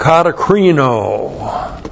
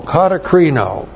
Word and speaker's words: katakrino: Looks like that in the Greek, D-I-A-K-O-N-I-A. Diakonia is ministry, and then katakrisis. Katakrisis, K-A-T-A-K-R-I-S-I-S. Katakrisis, one. Now, katakrisis comes from the verb katakrino. Looks like that in the Greek, K-A-T-A-K-R-I-N, long O, katakrino.